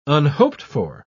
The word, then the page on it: unhoped-for: British English